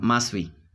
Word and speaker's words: Maswi: Oh, Maswi. That's spelled M-A-S-W-I. – Is the time you used to spell it as M-A-5? – Yeah.